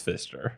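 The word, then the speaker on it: Pfister: And it's spelled with a PF, so it looks like Pfizer, but it's Fister.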